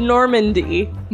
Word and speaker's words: Normandy: Said it right. Normandy. – Normandy, the way that it's spelled? – Yeah.